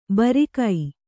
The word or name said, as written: ಭಾರಿಕ